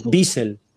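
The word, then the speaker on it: Wiesel: ¿Cómo se pronuncia? ¿Biesel? ¿Biesel, no? – Biesel, Biesel, sí.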